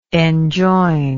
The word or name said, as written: enjoinment